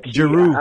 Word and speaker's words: Giroud: Like, Giroud.